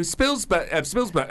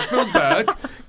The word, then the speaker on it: Spielberg: But the fact he didn't know who Spielberg. Oh, even I'm saying it wrong. Spielberg. God, I'm pronouncing it wrong after hearing Verdi.